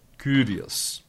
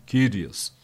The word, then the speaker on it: Kyrios: En la pronunciación moderna del griego sería Kyrios, pero parece que lo correcto era Kyrios, así como la U francesa, Kyrios.